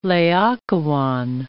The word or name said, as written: Laocoon